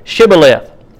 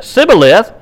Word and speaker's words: Shibboleth: If he answered, "No," they told him, "Please say Shibboleth." If he said Sibboleth, because he could not pronounce it correctly, they seized him and killed him at the fords of the Jordan.